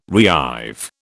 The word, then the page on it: reive: British English